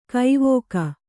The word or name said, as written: ಕವಿಕೆ